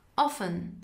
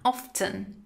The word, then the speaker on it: often: Often. Often. اغلب اوقات. بعضی ها تی رو هم تلفظ میکنن. Often. اما often رایج تره.